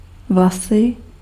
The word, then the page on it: haar: /ɦaːr/